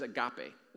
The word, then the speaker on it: agape: So the word is agape. It's kind of spelled like agape, A-G-A-P-E, agape.